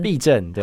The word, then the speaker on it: 痹: 那在中医来讲，它是那个字念作痹嘛，是不是？ – 痹症，对，痹症。